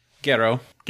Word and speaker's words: Jiro: So, like how do you pronounce Jiro in Japanese? Like, you're supposed to? Gero? – Gero.